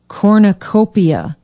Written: Cornucopia

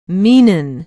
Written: meenon

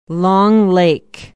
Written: long lake

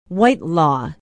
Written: whitelaw

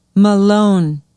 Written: malone

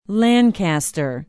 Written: Lancaster

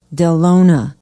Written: dellona

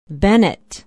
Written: bennett

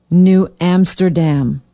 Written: New Amsterdam

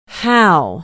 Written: how

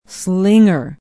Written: slinger

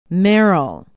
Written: Merrill

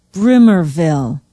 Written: bruemmerville

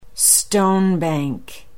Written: stonebank

